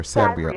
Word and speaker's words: Sabrio: But Kaitlyn um, Sabrio or Sabrio? Sabrio.